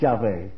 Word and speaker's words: Yahweh: As far as we know, in pronunciation, it's Yahweh.